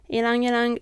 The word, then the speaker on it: Ylang Ylang: Just for reference, Ylang Ylang is spelled with Y-L-A-N-G. It is not necessarily spelled exactly how it sounds.